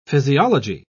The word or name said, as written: physiology